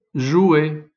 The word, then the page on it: jouet: son u; ou